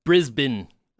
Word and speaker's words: Brisbane: Brisbane, as I've been told you're supposed to pronounce it, has caught a serial criminal, Poo Jogger.